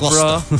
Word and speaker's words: Gloucester: It's like, I know, what's up, Gloucester.